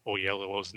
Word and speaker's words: Oyelowo: Maybe the only downside about Agent Callus is now that I can't get around not pronouncing David Oyelowo's name now. – But see, I think you just did it pretty well right there.